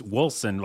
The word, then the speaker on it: Wolcen: We keep saying it wrong. It's Wolcen, like W-O-L-C-E-N, but you're saying Wolcen.